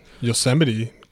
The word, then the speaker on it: Yosemite: Yosemite.